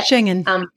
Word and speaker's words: Schengen: So Croatia is actually outside of that zone, that Schengen zone or Schengen, or how to say it? – Schengen. – Schengen. Okay, perfect.